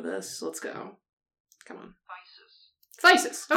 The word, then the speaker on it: phthisis: You were right. It's just thysis. I preferred fucking it up as hard as possible, but like... – It helps to know what we're talking about. – It does help to know what we're talking about. So it's thysis. If you hear me saying thysis, it's because it's spelled like that.